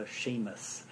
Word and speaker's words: SEAMUS: a Seamus. – Oh, wow. – Pronounce it in Irish.